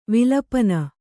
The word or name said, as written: ವಿಲಪನ